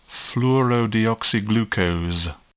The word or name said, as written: fluorodeoxyglucose